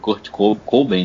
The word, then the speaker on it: Kurt Cobain: o cururu o Cururu que morreu aí, o, o Kurt Cobain, né? – Como é que é a é.